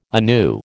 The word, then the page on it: anew: American English